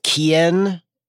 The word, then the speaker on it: Kean: Moise Keane, it's Kean, right? – Yeah, I think it's the thing is that I've heard it pronounced Kean, but it's I'm pretty sure it's Moise Kean.